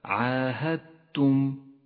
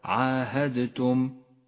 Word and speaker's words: عاهدتم: في الآية التالية الإدغام المتجانس بين حرف الدال وحرف التاء في كلمة عاهدتم الدال رمادية لأنها لا تلفظ وأما التاء فتشدد، هكذا عاهدتم وليس عاهدتم فإنه خطأ شائع.